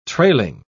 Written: trailing

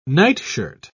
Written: nightshirt

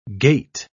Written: gait

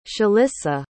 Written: shalissa

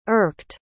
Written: irked

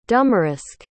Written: Dumaresq

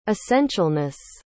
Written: essentialness

